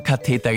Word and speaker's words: Katheter: Nein, Katheter. – Katheter, okay.